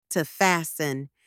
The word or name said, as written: to fasten